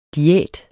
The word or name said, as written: diæt